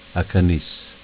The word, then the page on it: Acaniss: Dialetto